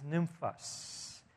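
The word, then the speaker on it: Nymphas: And then he says this, and the way to pronounce this is Nymphos. I'll just kind of transliterate. It's N-Y-M-P-H-A-S. Nymphos is her name.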